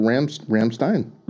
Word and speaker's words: Rammstein: you always pronounce the second letter. – Oh. – Always. – Okay, so Rammstein. – Rammstein, I with an I, yeah. – Because it's E-I, right? – Interesting.